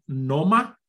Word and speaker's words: NOMA: NOMA.